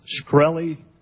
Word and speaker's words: Shkreli: Shkreli repeating the same answer to every question except one. – Is it pronounced Shkreli? – Yes, sir.